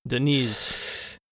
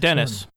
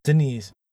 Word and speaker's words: Deniz: Perhaps there is a strong affinity for Deniz, not PS1. Dennis. – Deniz, not Dennis. No. Not St. Dennis.